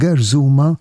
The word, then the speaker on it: gerżuma: Mela fil-kelma gerżuma iż-żu, is-sillaba żu għandha l-aċċent primarju.